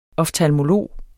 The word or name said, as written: OFTALMOLOGEN